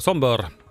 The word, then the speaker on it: Sombr: Öva på det lite fonetiska här. Sombr. Sombrör. Sombör. Mm, så ska det uttalas.